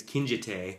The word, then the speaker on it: Kinjite: Next is Kinjiti Forbidden Subjects. Is that how you say it? – No. I think it's Kinjite.